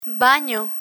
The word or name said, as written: bano